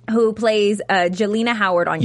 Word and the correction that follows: Jelena: Yelena, your show. – Yelena. – Okay. Yeah. So the J is pronounced with the Y. Yelena.